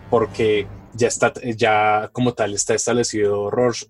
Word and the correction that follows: Rorschach: ah, siempre se, no pronuncio. – Rorschach, es que es raro ese nombre.